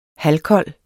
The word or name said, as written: halvkold